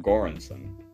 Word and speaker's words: Göransson: Göransson.